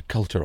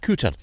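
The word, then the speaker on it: Culter: Well, we've moved beyond Aberdeen City, inland about 8 miles, the small town, which has Peter Coulter on the map, but I'm told that people who live here pronounce it Cooter.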